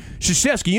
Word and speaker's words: Krzyzewski: It's the most random put together of letters that there is possible, but it's pronounced Krzyzewski. You know that?